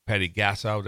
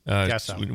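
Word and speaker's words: Patty Gasso: The last time it happened, it was Oklahoma, was the one that became the Patty Gassow. Did I say that right? – Yeah, Gassow.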